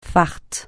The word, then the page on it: fart: francais